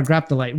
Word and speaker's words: Graptolite: He was also the puppeteer for the Graplodite, or Graptolite, one of the Graptolites. I said it wrong again. We talked about the Graptolite. Graptolite.